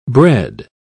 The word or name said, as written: bred